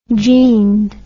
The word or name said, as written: jeaned